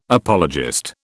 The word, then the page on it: apologist: British English